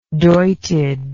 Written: doited

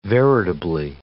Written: veritably

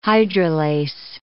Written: hydrolase

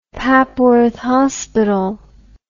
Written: papworth hospital

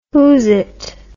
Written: whosit

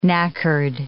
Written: knackered

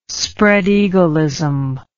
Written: spreadeagleism

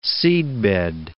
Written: seedbed

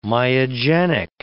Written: myogenic

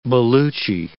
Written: Baluchi

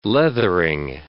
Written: leathering

